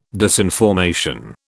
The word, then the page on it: disinformation: British English